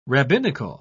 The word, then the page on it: rabbinical: British English